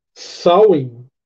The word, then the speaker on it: Samhain: Inclusive, Samhain é a pronúncia correta em irlandês. Em gaélico escocês seria Samhain, só que com U.